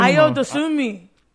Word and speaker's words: Ayo Dosunmu: And watch, people like Illinois' Ayo Dosunmu. I don't know how to pronounce that name. I'm going to be honest. Cliff, you got me help on this one? – Ayo Dosunmu.